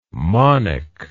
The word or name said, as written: monic